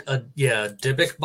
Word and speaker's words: Dybbuk: Do you know the basic mechanics behind, and how do you say it again? A Dybbuk box? – Dybbuk box. – D-Y-B-B-U-K.